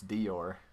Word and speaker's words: Dior: But, like, if you're pronouncing it, like, from the Elvish language, it's Dior. – Dior. – Yeah. – Like Eeyore? – Yeah, but Dior. – With a D.